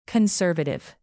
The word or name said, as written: conservative